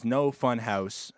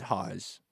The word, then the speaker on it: No Fun Haus: It is No Fun Haus. House spelt as H A U S, not Haas. I made that mistake.